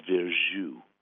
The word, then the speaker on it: Verjus: Miles, M-I-L-E-S, at Robin Hood Radio. Somebody asked me, I have a bottle of Verjus. That's spelled V-E-R-J-U-S, Verjus.